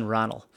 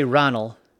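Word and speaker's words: Raghnall: In the Gaelic, it's R-A-G-H-N-A-L-L, something similar to that. And you don't pronounce G-H, so it looks like Ranulf.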